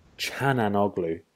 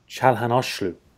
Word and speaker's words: Çalhanoğlu: Also, I realized recently I've been saying this wrong, but I thought it was Hakan Çalhanoğlu. But it's... – I just saw that as well. – Çalhanoğlu. – Och. – Çalhanoğlu. Çalhanoğlu. There you go.